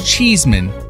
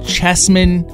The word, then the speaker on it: Cheeseman: My last name, I don't think it's that hard because my last name is Cheeseman. The two E's make it long, but what trips everybody up is the fact that there's no E after the S. And I get called Chessman, and my new wife, Kirsten, is figuring that out firsthand.